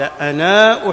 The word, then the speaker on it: أنا: حدثنا الأصل في ألف أنا أنها لا تلفظ إلا إذا جاء بعدها همز ففيه لغتان للعرب فإن لم يكن بعدها همز فالأصل أنها لا تلفظ.